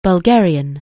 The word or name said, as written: Bulgarian